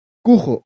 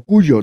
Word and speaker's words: Cujo: Cujo o Cuyo, depende cómo lo pronuncies.